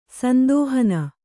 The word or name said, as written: ಸಂದೋಹನ